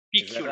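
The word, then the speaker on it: PQLite: You said Piculite, or how do you spell that? – Piculite. – Ah, PQ for, Lite. – PQ Lite. – Right? Oh, I see. With, it's like product quantization Lite version.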